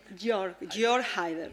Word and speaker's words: Jörg Haider: Jörg Haider. Como se pronuncia? – Jörg Haider.